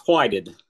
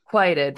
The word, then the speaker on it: Whited: Is it Brandon Whited? – It's whited. – Whited. Brandon Whited.